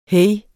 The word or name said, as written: hey